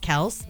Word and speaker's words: Kelce: They said in an interview that technically it's pronounced Kelce, but once they both started like college football, it just got mispronounced so much.